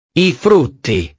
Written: i frutti

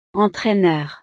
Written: entraineur

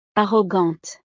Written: arrogante

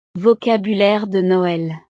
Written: vocabulaire de noel

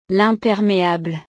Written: l'imperméable

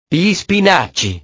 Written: gli spinaci

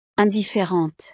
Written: indifferente

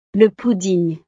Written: le pudding

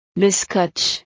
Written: le scotch

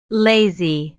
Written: lazy

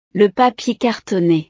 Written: le papier cartonee

